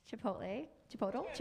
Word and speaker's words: Chipotle: that I'm supposed to shout out the wonderful Chipotle.